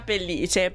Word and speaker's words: Pellice: Stanno spostando l'accento per te. – Quindi Via Pellice, perfetto.